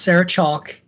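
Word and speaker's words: Chalke: Speaking of favorite television shows, her name is pronounced Sarah Chalk, the E is silent, and fuck you, Tony, for hating Scrubs.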